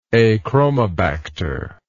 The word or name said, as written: achromobacter